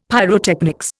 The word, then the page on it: pyrotechnics: Indian